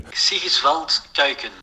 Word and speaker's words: Sigiswald Kuijken: Sigiswald Kuiken.